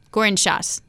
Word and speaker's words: Gourinchas: So, Dani Burger was subbing for Guy on television, and she and I sat down with chief economist Pierre Olivier uh, Gournichage. Nope, I said that wrong. Gourinchas. There we go.